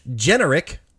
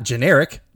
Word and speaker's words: Generic: Bobby Generic, not generic, Generic is how it's pronounced, and also the dad, Howard, were voiced by Howie Mandel.